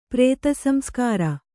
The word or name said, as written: ಪ್ರೇತಸಂಸ್ಕಾರ